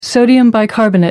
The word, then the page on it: sodium bicarbonate: American English